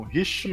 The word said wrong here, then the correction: Rickmansworth: Rick, né? Rickmansworth.